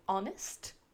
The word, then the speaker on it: honest: Encore une fois, H initial ne se prononce pas. Je ne fais pas de HE au début de mon mot, honest.